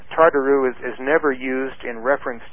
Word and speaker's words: tartaroo: That's the word Tartaro. It's spelled T-A-R-T-A-R-O-O.